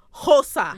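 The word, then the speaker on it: Xhosa: It's X-H-O-S-A. – Hosa? – Hosa.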